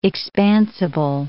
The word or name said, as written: expansible